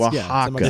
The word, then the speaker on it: Oaxaca: I don't think you're pronouncing that correctly. – Yeah, see, you right away. – A-C-A. – One more time. One more time. Oaxaca. Play that. – Oaxaca.